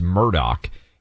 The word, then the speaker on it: Murdaugh: The guy's name is, it looks like it should be pronounced Murdaugh. It's actually pronounced Murdaugh.